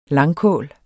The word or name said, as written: langkål